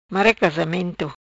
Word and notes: Mara Casamento: Patua

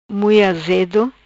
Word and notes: Mui Azedo: Patuá

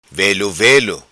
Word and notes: Velo Velo: patua

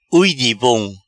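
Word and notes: Ui di bom: Patua

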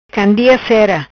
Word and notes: Candia Cera: patua

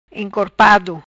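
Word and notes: Encorpado: Patua

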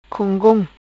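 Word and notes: Gongom: patua

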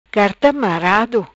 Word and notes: Marado Carta: Patua

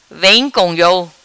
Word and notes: Vem Co Iou: patua

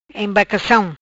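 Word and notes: Embarcacam: Patua

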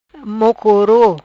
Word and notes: Mocoro: Patuá

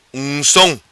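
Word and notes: Ungsom: Macanese Patuá

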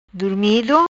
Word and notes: Durmido: Patua

